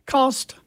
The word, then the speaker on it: Coste: Well, we're going to attempt to do that by celebrating a birthday here, the birthday of Napoleon Cost, and there's an E at the end, which I normally would pronounce as Coste, and I think I've been doing that over the years, but I think I've learned this morning that I should not have been doing that.